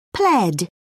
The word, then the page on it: pled: British English